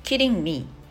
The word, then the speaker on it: Kidding: Today's phrase Kidding me Kidding me 冗談でしょこれちょっと発音気をつけてくださいね。あの、 D の発音強めにちゃんと言わないと、キリングミーになっちゃうので、まあ別にいいんですけど、それでもね。